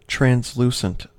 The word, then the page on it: translucent: American English